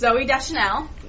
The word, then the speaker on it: Zooey Deschanel: On the next is Zooey Deschanel.